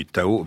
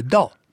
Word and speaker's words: Dao: Oui, le mot a été francisé, le Tao, mais en chinois on dit le Dao.